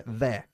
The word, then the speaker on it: they're: La contracción se pronuncia there. – There. – There. Una sílaba.